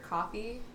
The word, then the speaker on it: Coffee: It's Heather Coffee, just like the drink.